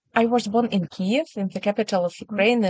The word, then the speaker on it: Kyiv: The most good pronunciation, Kyiv. Yes, the true pronunciation, Kyiv.